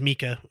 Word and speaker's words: Mika: And it's like, oh, so maybe he does want it pronounced Mika, but then all the like I said, I finally found a pronunciation. It is Mika.